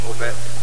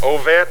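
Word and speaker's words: Ovett: I can't pronounce the name of the town. It's worse than Greek. – Ovet. – Ovet, all right.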